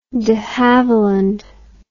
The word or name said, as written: de havilland